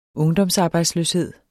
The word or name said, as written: ungdomsarbejdsløshed